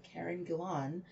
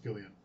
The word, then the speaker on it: Gillan: watched Gunpowder Milkshake with Karen Gillan. Um, and who have always pronounced, pronounced Gillian. I don't know why. Yeah, I guess you assumed it would have an extra I, and it yeah. doesn't.